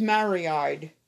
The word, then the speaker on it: Mariide: My name is Marie-Eyed. – Spell it for M-A-R-I-I-D-E.